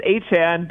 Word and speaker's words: Etienne: And there was a video from his time in college where he said, you know, it actually is Achan, but the other way is fine too.